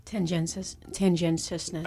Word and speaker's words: tendentiousness: Yellow journalism is news reported with tendinaciousness. – Did I say it right that time? – Tendentiousness.